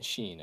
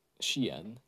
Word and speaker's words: Shein: Gosh, I hope I'm pronouncing that. Let me check my pronunciation. Uh, it's or uh, S H E I N, Sheen, as some people say. But uh, uh, let me check my pronunciation. Uh, I I should link to this also. She. Shein. Oh, I I, I kind of said right.